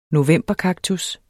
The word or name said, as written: novemberkaktus